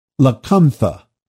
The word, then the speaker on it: Lacunza: Rabbi Paul, not Apostle Paul, Rabbi Paul, which was the pen name of a Jesuit, Emmanuel Lacunza, they pronounce it Lacuntha.